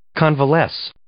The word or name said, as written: convalesce